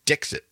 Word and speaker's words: Dixit: D-I-X-I-T. – Dixit. Almost like Dixieland or something. – Yeah. It's like that, but it's a T instead of an E.